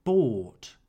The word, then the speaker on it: bought: By in the simple past is pronounced bought. The GH is silent.